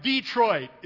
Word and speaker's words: Detroit: From Detroit is how you say it, right? With the emphasis on the Detroit down to Houston.